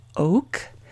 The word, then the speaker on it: Ok: As noted in my horoscope blog, traditionally, the first day of this Oct, spelled O-K or O-C sometimes, pronounced Oct,